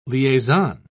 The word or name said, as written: liaison